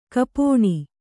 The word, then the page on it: ಗೋಪನ: Kannada